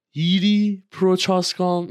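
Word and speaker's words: Jiří Procházka: هیری پروچاسکام این تلفظ درستش بود که ما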